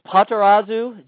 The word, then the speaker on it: Potarazu: It's Potarazu, just kind of all no emphasis. Just kind of there you go.